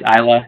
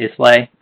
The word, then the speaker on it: Islay: It's Islay, not Islay. – Sorry. – So the S is kind of like an island.